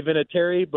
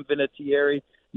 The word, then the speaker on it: Vinatieri: So, yeah, it's we say Vinatieri, but I've heard a lot of people pronounce it with more enunciation. So if you want to call me Adam Vinatieri, knock yourself out. I don't mind either way.